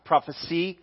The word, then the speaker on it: prophecy: Prophecy, got the letter C. Think C is how to pronounce it.